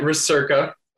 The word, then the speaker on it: Recerca: And is it Recerca or Recerca? Did I butcher any pronunciations? – We are calling it Recerca.